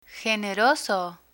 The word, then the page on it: generoso: Spanish